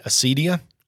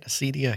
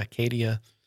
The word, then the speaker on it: acedia: On acedia. Is that how you say it? Acedia, Acadia, slothfulness, acedia.